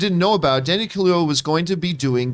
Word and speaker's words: Kaluuya: Kaluuya, thank you.